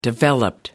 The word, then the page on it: developed: American English